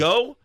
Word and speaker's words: geaux: My mom and dad and whole family actually lives in northern Louisiana, so they taught me how to, I think it's spelled G-E-A-U-X, yes. Go.